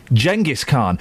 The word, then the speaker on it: Genghis Khan: Wrong. Wrong, suckers. It's a soft G. Genghis Khan.